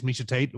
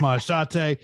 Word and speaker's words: Machate: If you look at Machate, if you just like peek at your line, you might think it says Misha Tate the way it's spelled out, but it's Machate.